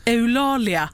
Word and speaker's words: Eulalia: Jag tror inte att det har funnits, jag tror att du menar Eulalia. – Ja, det är, för jag har bara läst det, jag har aldrig hört någon uttala det. – Eulalia.